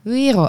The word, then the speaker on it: Wero: Want je moet eigenlijk gewoon denken aan hoe spreek je euro uit. Nou, sorry, zo spreek je ook Wero uit. Dus in Nederland kun je gewoon Wero zeggen.